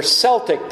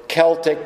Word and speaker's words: Celtic: You think of the Gauls regarding France, and they were Celtic people, or Celtic, depending on how you prefer to pronounce it, that also peopled like Ireland and northern Spain, but they also were in southern Turkey in Asia.